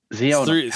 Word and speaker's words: Zeono: Is it Zeono? – Zeono. Three syllables. Just remember it that way.